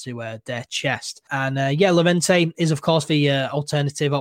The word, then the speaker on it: Llorente: It should be pronounced Llorente because it's double L.